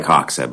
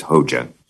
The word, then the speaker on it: Hoxha: It's spelled H-O-X-A-H-A. So it looks like Hoxha, but it's Hoxha.